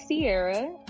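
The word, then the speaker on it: Ciera: So, my name is Sierra, and you can follow me on Instagram and Twitter as Sierra's Interlude.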